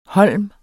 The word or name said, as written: holm